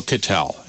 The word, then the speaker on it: Kittell: Kittel, and that last name is K-I-T-T-E-L-L.